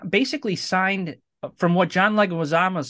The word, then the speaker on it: Leguizamo: He was supposed to Leguizamo.